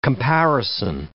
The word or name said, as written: comparison